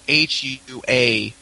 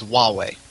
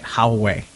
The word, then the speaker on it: Huawei: And for those of you wondering who are listening and may not be able to actually see, Huawei is the company, the name is spelled Huawei. So if you've ever seen that at a store and wondered how you say it, who it is, it's Huawei. – Yeah. See, now I was always saying it Huawei. Mm-hmm. Because it has an H, but yeah, it's Huawei.